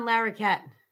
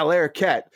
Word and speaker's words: Larroquette: How do you say his name? – John Larroquette. – Yeah, Larroquette.